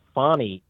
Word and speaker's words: Fani: And so what Fani, I've been mispronouncing her name, and I do apologize. It's Fani Willis.